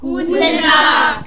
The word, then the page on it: guten Tag: german